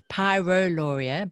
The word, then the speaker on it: pyroluria: And it's pyroluria, by the way, just to be clear. That's okay. And it's, I'll spell it P-Y-R-O-L-U-R-I-A for anyone who wants to look it up.